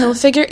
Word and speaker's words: Hilfiger: the vitamin C lipstick shade was made by Tommy Hilfiger. Wow. Is that did I say that right? – Tommy yeah. Hilfiger, yeah, uh-huh.